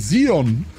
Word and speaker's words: Xeon: Que não é Xeon, é Xeon.